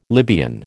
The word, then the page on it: Libyan: American English